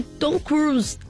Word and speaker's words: Tom Cruise: com participação até de Tom Cruise.